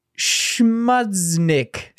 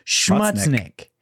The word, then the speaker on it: Schmutznik: What? It's Schmutznik. – Yeah, Schmutznik. – Oh, my God. To be sure, like, to let everybody know, the way you spell that is S-C-H-M-U-T-Z-N-I-K. Schmutznik. That's right, baby.